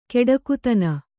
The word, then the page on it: ಕೊಡುಗೈತನ: kannada; koḍugaitana